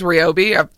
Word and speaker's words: Ryobi: I love those battery-operated things, Ryobi.